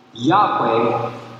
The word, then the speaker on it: Yahweh: But the original pronunciation was likely closer to Yahweh or Yehovah.